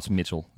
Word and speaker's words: Mitchell: My last name is, it's hard to pronounce, but it's Mitchell. You know, it's not crazy.